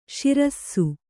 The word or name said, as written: ಸಿರಸ